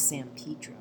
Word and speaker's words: San Pedro: San Pedro.